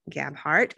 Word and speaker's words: Gabhart: And today I have author Anne Gabhart.